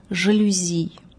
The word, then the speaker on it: жалюзи: Происхождение слова французское, следовательно, произносится оно с ударением на последнем слоге – «жалюзи».